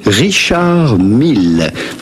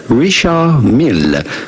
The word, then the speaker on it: Richard Mille: Do not pronounce the D to Richard. It's not like in English, you do not say Richard, but rather Richard. In English, Richard Mill. To pronounce the double L. Richard Mill, with the typical French or Swiss French pronunciation, Richard Mill.